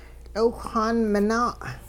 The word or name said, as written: tL'iwhxaan minaa'